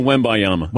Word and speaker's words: Wembanyama: How do you pronounce it? Wembanyama? – It's still Wembanyama.